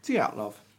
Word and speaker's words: Dyatlov: Are we happy with my pronunciation of Dyatlov? – Yeah, that's how it's said, yeah. – Dyatlov.